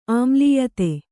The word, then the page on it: ಆಮ್ಲೀಯತೆ: āmlīyate